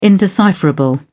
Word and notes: indecipherable: British English

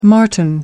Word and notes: martin: American English

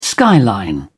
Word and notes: skyline: British English

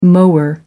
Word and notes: mower: American English

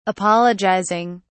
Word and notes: apologising: British English

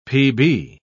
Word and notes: pb: British English

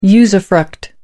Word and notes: usufruct: American English

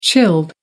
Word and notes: chilled: American English

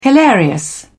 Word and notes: hilarious: British English

